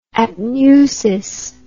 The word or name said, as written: apneusis